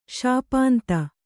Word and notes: ಶಾಪಾಂತ: śapānta

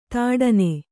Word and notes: ಥಟ್ಟನೆ: kannada

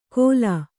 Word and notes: ಘೋಲ: kannada

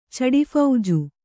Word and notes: ಚಡಿಫೌಜು: Kannada

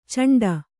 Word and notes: ಚಂಡ: caṇḍa

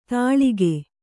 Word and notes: ತಳಿಕೆ: Kannada

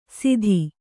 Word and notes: ಸಿತಿ: siti

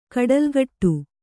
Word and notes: ಕೊಡಲಿಗಡಿ: koḍaligaḍi

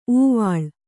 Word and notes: ಊವಾಳ್: Kannada

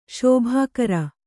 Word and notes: ಶುಭಕರ: kannada

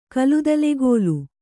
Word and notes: ಕಲುದಲೆಗೋಲು: kaludalegōlu

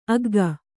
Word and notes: ಆಗ್ಗೆ: kannada

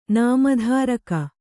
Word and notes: ನಾಮಧಾರಕ: nāma dhāraka

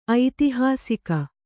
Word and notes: ಐತಿಹಾಸಿಕ: aitihāsika

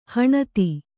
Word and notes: ಹಣತಿ: Kannada

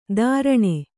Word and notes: ಧಾರಣೆ: Kannada

